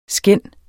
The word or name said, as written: skænd